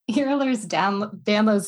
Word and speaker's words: Ehlers-Danlos: And that is, and I was confessing to Dr. Mack that I've never pronounced this correctly in my entire professional career. She coached me. Let's see how we do. Ehlers-Danlos syndrome.